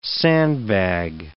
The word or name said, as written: sandbag